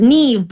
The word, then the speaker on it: Neve: So, yes, it's Neve, but it's actually originally a Gaelic word. I think the correct quote-unquote spelling would be, like, even more complicated, like N-I-A-M-P-H, or, you know, all these silent letters in there.